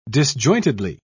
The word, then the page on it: disjointedly: British English